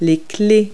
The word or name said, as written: wrenches